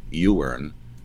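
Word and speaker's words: Iwerne: I'll just pause and say the Titus Trust is the entity that ran the Ewern, it's spelled I-Wern, Ewern camps,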